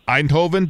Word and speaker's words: Eindhoven: Now, your home city, if I'm not mistaken, correct me if I'm probably going to say it wrong, Eindhoven, did I say that correct? – Yeah, that's good.